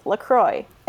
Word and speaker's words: LaCroix: LaCroix pronunciation, and it says, according to the official site, it is pronounced LaCroix. It rhymes with enjoy.